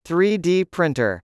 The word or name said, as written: 3D printer